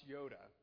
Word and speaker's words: iota: This is the Greek word, it's actually pronounced Yoda. But I thought if I pronounce a Yoda, you're going to go, "Isn't he the somewhat diminutive, highly wrinkled Jedi Master from Star Wars fame?" No, this is I-O-T-A, Yoda.